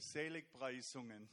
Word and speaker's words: Seligpreisungen: How do you say it? – Seligpreisungen. – Seligspreisungen.